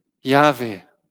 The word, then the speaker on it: Yahweh: Und wir erkennen die vier hebräischen Konsonanten dieses Eigennamens und wir wissen auch, wie wir diese Konsonanten aussprechen. Yahweh.